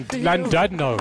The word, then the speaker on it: Llandudno: In Llandudno, bro.